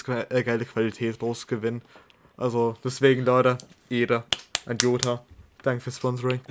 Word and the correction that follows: Joto: Da steht also, Joto. Also. Das ist ein O.